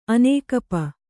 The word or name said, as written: ಅನೇಕಪ